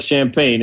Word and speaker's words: Champagne: So Justin Champagne, his name looks like champagne. It's pronounced Champagne.